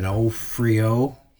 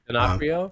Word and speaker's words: D'Onofrio: Uh, how do you say his name? – Donof- yep. D'Onofrio. He was great as uh, as the lawyer. – D'Onofrio.